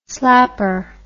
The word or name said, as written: slapper